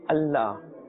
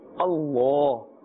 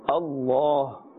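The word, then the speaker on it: Allah (الله): As you can see, there is a vertical fatha, and therefore we must pull according to the duration of one alif, and we must pronounce this with a full mouth, not with a flat sound. We should not say Allah.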